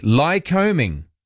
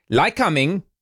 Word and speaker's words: Lycoming: Lie combing. Although there's no, it's not like combing your hair, not that kind of combing. It's spelt L-Y-C-O-M-I-N-G. Lie combing. It's probably why they said lie combing.